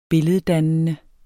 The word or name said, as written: billeddannende